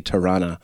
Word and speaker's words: Toronto: I try to pronounce the T's now because since I moved out here, I realize we only say Tirana.